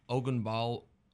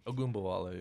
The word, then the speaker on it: Ogunbowale: LeSean McCoy, Dare Ogunbowale, that's how you say it. – Ogunbowale, yeah.